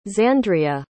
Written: xandria

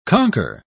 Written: conker

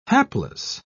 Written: hapless

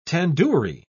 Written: tandoori

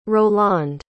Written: rolande

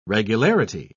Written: regularity

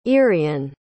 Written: eirian

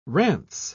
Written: rance

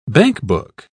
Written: bankbook

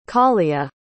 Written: callia

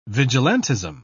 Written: vigilantism

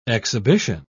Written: exhibition

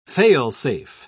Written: fail-safe

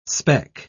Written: speck

